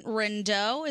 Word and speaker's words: Rindeau: His name is Vincent Rindeau. Is that how we pronounce it? – Yeah, it's a French name, R-I-N-D-E-A-U or something like that.